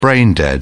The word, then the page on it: brain dead: British English